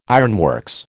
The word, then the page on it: ironworks: American English